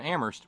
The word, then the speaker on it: Amherst: So first, a note from Terry in Amherst, where only the H is silent.